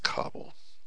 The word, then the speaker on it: Kabul: I guess you pronounce Kabul. The whole thing is a big Jewish cabal, actually. But it's pronounced Kabul.